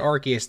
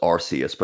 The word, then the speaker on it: Arceus: What's the correct way then? – Arceus. – Originally, yeah, the original pronunciation was Arceus, but they changed it because of in the UK, the word arse, they didn't want it to have that same sound in there, so they changed it phonetically to become Arceus so that people don't have that word in there.